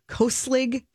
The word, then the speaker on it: Koselig: But he is gifting I'm not sure how to pronounce their name. Koslig Candles. – It sounds right. – K-O-S-E-L-I-G.